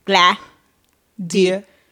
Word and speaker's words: Gladira: But, you know, these other fools be talking about Gladiria or Glandira, but my name don't got no N in it, you know. I don't know what the hell. G-L-A-D. Come on. Phonics. – Gladira. Oh, that's three syllables.